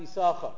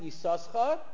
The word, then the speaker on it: Yissachar: There's apparently a silent sin. And there are those who actually bring down that you're supposed to pronounce it Yissachar till you hit,